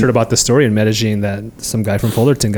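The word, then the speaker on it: Medellín: That's how you say it, Medellin. – Medellin. Medellin, Like yeah. you know, people say Medellin.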